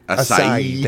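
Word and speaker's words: açaí: Achaia? A-C-A-I. – I always forget how to say this. – I don't remember word. – Either. – I purposely didn't look it up beforehand. – Acai. – Acai. – Thank you. Acai.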